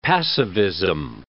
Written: passivism

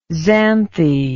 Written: xanthe

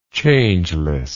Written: changelessness

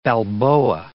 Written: balboa

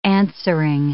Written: answering

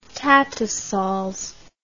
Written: tattersalls

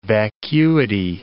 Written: vacuity

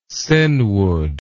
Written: senwood

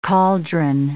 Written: cauldron

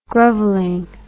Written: groveling

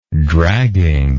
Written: dragging

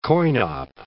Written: coin-op

